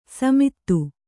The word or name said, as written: ಸಮೆತ